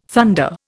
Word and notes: thunder: Indian

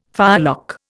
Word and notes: firelock: Indian

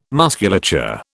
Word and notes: musculature: British English

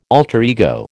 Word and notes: alter ego: American English

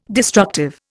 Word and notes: destructive: Indian

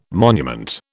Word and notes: monument: British English

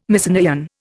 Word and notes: Mycenaean: Indian

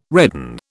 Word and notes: redan: British English

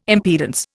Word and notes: impedance: Indian

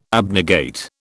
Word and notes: abnegate: British English

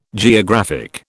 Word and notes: geographic: British English

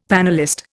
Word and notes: panellist: Indian

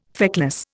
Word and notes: feckless: Indian

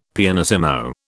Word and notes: pianissimo: British English

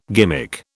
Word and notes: gimmick: British English

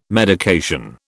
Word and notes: medication: British English

